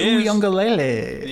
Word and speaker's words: Uiagalelei: I know DJ Uiagalelei.